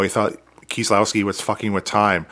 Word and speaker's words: Kieslowski: Kieslowski.